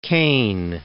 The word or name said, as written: Caine